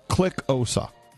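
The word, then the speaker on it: Xhosa: It's click OSA. – Okay. – OSA. – Oh, you have to click first? – You have to click.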